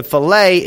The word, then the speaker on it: Philae: Let me spell that for you. P-H-I-L-A-E. I'm calling it Philae. Like fish filet, huh?